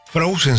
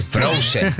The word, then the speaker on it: Frozen: Me dice, consultá si viene Frozen, se dice. – Sí, muy bien. – Frozen.